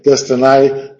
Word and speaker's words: destiny: de hecho, tenemos que forzarlo mucho para decir destiny, que seguramente es como se pronunciaba entonces, para que rima con cry.